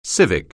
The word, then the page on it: civic: anglais